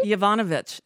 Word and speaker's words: Yovanovitch: He's having a real bad day, considering Yovanovitch or whatever her name is. Did I say that right? – Yovanovitch, absolutely.